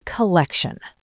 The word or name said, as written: collection